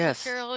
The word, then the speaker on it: Mercurial: I would think Mercurial because she's silver.